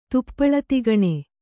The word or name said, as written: ತುಪ್ಪಳತಿಗಣೆ